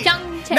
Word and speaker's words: Pyeongchang: No, see, Pyeongchang.